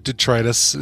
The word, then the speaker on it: detritus: So that, you know, turning it on for a while, yeah, it'll flush some big detritus. Is that detritus? – Detritus. That's right. – Detritus. Yeah. I did say it right.